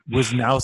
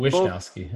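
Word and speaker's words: Wishnowsky: They bet Wishnowski. Both, I don't know. – It looks like it's Wishnowski. – The H is silent, guys.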